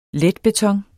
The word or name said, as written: letbeton